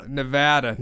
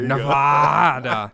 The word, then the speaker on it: Nevada: The couple would eventually travel to Reno, Nevada to am I saying this right? I got yelled at for not saying it right. – Nevada. – No, I could just do that just for her. Nevada.